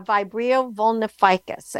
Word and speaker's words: Vibrio vulnificus: But there is a bacteria that's called um, Vibrio vulna. Uh, let me get this right. Vulnificus, uh, Vibrio vulnificus, and it's spelled V I B R I O V U L N I F I C U S.